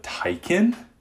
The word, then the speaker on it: Taycan: I actually found out, I just want to say before I start today's episode, I found out that it's actually pronounced the Porsche Taycan. Taycan. Porsche actually released a whole video on YouTube on how to pronounce it. You have to release a video on how to pronounce a car's name. Maybe you should consider a different name, but that's okay. So now we know it's Taycan, not Taycan, Taycan.